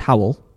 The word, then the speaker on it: Hywel: His name's Howell, but at the end, but it's spelled H-Y-W-E-L. – Hi. – So at the end, he said, pronounce like towel with a H just in case, so that's perfect.